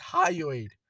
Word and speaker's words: hyoid: It got violent on Raw, and she suffered a quote unquote a fractured hyoid bone in oh my goodness, how do you pronounce this word? It's hyoid.